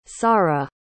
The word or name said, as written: saara